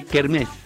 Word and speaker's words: kermesse: Se escribe kermesse, pero se dice kermés. – Ah, es como querosene, se dice querosen.